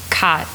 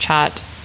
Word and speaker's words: khat: there's this drug called khat. Sometimes it's pronounced chat.